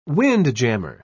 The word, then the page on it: windjammer: British English